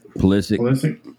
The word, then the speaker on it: Pulisic: Pulisic, is that how you say his name? Christian Pulisic? – Pulisic.